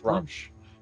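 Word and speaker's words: Gruumsh: I wouldn't. – Okay. – I was just saying, I think that's Grumsh. – Yeah. Yeah.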